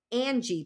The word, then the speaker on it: Anji: And they have a program in China called Angie Play. It's spelled A-N-J-I, okay?